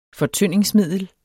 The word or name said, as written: fortyndingsmiddel